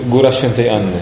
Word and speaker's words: Góra Świętej Anny: Now, last weekend we had a retreat seminar in, how do you pronounce it, Góra Świętej Anny? – Góra Świętej Anny.